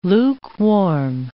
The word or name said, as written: lukewarm